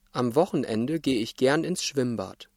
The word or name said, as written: Schwimmbad